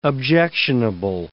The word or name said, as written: objectionable